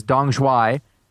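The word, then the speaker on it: Đồng Xoài: The book of the month for May, as you may have noticed on the site, is Dong Zhuai. And that is how to pronounce it. I checked.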